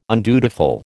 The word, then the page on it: undutiful: American English